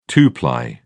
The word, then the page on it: two-ply: British English